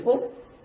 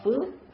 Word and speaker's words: phước: Chúng ta có thể dịch nói ra hai từ, cũng có thể đọc là phúc mà cũng có thể đọc là phước, đều là chữ đó cả và đồng một nghĩa như nhau.